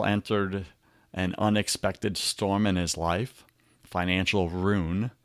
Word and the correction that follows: ruin: my wife corrects me, ruin, it's two syllables, honey, two syllables.